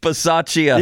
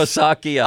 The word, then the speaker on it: Bisaccia: It was like Rich Scarlacchia or something. I'm close. How do you pronounce his name? – Rich Bisaccia. – Yeah, I was very close. – Or Bisaccia.